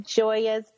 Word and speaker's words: Gioia's: So thanks to our RVFTA listener, Jody, she told us to go to, I think it's pronounced Gioia's Deli. It's spelled G-I-O-I-A. – That is how you pronounce that name in general, for sure.